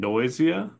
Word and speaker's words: Noisia: like nausea? – It's Noisia, Noisia, I think.